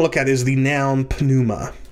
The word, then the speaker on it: pneuma: In English, when we have a P before an N, the P is silent. But in Greek, you would say pneuma.